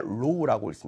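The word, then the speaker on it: 로우 (ρ): 자, 여기 보면 이상한 문자교 하나 있는데, 이 문자교를 우리가 읽을 때는 로우라고 읽습니다.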